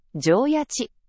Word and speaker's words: 上焼: 二つ目が、上焼き。上焼ち、と読みます。